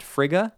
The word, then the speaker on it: Frigga: But within this movie, Thor's mom, Thor's mama, I think it's pronounced Frigga. I had to look it up, as in, you got to be friggin' kidding me,